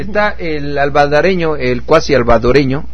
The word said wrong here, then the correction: alvaradeño: Es que no sé cómo se dice, ¿albaradeño? Alvaradeño, sí.